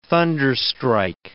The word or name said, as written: thunderstrike